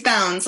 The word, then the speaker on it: Bounds: It's Bounds, like out Bounds. Of Bounds.